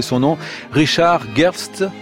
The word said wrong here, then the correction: Gerstl: Gerstl, absolument.